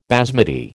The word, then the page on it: basmati: American English